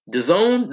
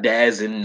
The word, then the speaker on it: DAZN: Zone, that's how you pronounce it, by the way. Not Dazin, not D-A-Z-N, it's Zone.